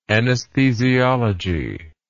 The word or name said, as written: anaesthesiology